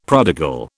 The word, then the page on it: prodigal: British English